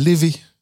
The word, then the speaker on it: Laufey: Qu'est-ce qu'on s'écoute après On s'écoute une chanteuse qui s'appelle Lévé. Ça s'écrit L'OFE, L-A-U-F-E-Y.